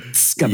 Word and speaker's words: Tsgabu: And so I listened to you saying it in a recording, but I couldn't make that sound. I was like, skabu, yes, skabu. Yes.